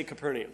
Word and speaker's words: Capernaum: Sometimes people pronounce this word Capernaum, Capernaum, Capernaum. I've heard all sorts of different pronunciations. You go with what sounds best to you. I'm going to say Capernaum.